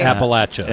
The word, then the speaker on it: Appalachia: I was kept calling it you know, I had a long A in there instead of the short A. – It's Appalachia.